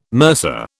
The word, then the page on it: mercer: British English